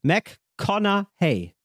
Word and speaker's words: McConaughey: Also, viele sprechen es so aus. McConaughey oder McConaughey. Und richtig ist McConaughey. McConaughey. – McConaughey. – McConaughey. – Matthew Mit stummen G. – Mac Matthew McConaughey.